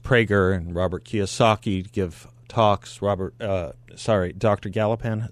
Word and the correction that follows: Gopalan: is that his last name? – Dr. Gopalan. – Gopalan. I always mispronounce it, and I apologize for that.